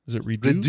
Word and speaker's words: Redux: Redux? – Is it like the French thing? Redux? – French, yeah. – Freaking French bullshit. Who knows, man? Those guys are their French words. – Hey, Redux.